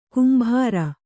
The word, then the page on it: ಗಂಭೀರ: Kannada